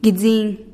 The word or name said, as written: gidziin